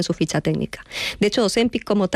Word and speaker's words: Ozempic: Estos medicamentos como Ozempic, que ahora sí lo digo bien, Ozempic, salieron al mercado para tratar no la obesidad, sino la diabetes.